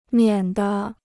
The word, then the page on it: 免得: miǎn de